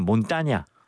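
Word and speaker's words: montaña: 원래는 몬따냐.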